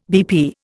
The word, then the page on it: BP: Indian